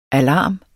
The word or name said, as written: alarm